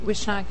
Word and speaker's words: Wischnack: My name is Julie Wishnack, and I'll spell that W-I-S-C-H-N-A-C-K.